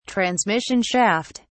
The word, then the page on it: transmission shaft: British English